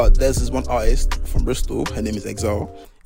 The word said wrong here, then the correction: Xyzelle: It's actually pronounced Zyzel. X-Y-Z-E-L-L-E.